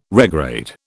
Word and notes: regrade: British English

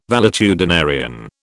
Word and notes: valetudinarian: British English

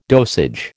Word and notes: dosage: American English

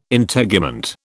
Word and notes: Integument: British English

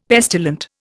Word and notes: pestilent: Indian

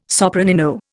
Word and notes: sopranino: Indian

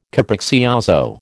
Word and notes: capriccioso: American English

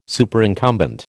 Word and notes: Superincumbent: American English

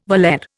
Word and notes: baler: Indian

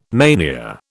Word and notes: mania: British English

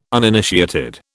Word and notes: uninitiated: British English

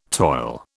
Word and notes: toil: British English

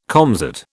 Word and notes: comsat: British English

